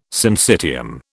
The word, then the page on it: syncytium: British English